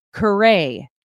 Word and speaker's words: Carey: By the way, Ethan pronounced his name a couple times off the top there for emphasis, because I said it wrong. But to my credit, I looked up the pronunciation, because I am not a St. Louis native, born and raised Cardinals diehard fan like he is. And it said Carey.